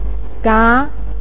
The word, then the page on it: ga: 2